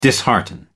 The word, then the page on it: dishearten: American English